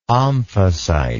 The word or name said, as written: omphacite